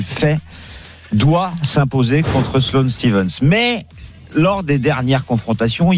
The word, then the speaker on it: Stephens: Alors ça s'écrit Stephens, mais ça se dit Stephens.